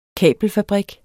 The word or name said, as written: kabelfabrik